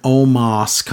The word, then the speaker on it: Omos: Omos is over. Now it's Omos again. So the emphasis on Moss, even though that's how he said to pronounce it, mm-hmm.